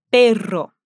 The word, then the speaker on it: perro: Pero, pero, perro, perro. Nesse caso, confundir os sons faz mudar a palavra, então lembre-se que os dois R's precisam ser pronunciados com um som forte.